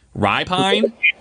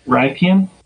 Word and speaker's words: Rypien: Rypien? – Ripine, Rypien, Rypien versus uh, versus the New York Jets.